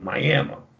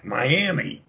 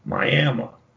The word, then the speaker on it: Miami: You're in Oklahoma, so the locals call it Miami. And if you go in there, you're from out of town, and you call that town Miami, they're going to give you some rough looks because they're not from Florida. They live in Oklahoma, and it's pronounced Miami. That's the way you have to pronounce it.